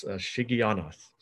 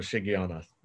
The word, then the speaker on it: Shigionoth: So the correct pronunciation for the word at the end of verse 1 is Shigionoth. So I even old people like myself learn something new every day. So Shigionoth, all right?